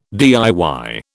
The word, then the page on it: DIY: British English